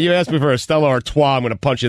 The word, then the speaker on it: Stella Artois: Stella Artois.